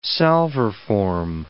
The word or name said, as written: salverform